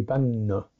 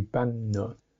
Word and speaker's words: banna: But in Pali, that would be banna. The Pali way of saying those words would be banna. Banna and banana. Banana. Short A, banana.